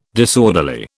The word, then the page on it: disorderly: British English